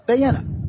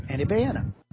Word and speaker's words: Beyene: Again, his name is spelled B-E-Y-E-N-E, and it's pronounced Bayena. Andy Bayena.